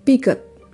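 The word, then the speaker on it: Peugeot: Uh, eh, Pugot, misalnya, bukanlah teman imajiner dari CEO Pugot, Pigot, Pigot ya. Tadi bacanya pijet, pigot, pugot. Sekarang oke, okay, pig, pigot aja pigot.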